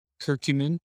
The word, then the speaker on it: curcumin: Curcumin. Curcumin, is that how you say it? Yeah. – Yeah, curcumin.